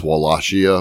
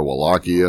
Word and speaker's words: Wallachia: I've heard it pronounced a couple of ways. – Well, I've never heard of it. – It's Wallachia or Wallachia.